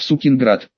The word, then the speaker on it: Сукинград: Все это были поселения, через которые должна была пройти восточная железная дорога, в Сукинград. Последний город нужно произносить с ударением на «и», назван он в честь землепроходца и покорителя Сибири Василия Сукина, основавшего в 1586 году Тюмень.